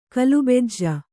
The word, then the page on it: ಕಲುಬೆಜ್ಜ: kalubejja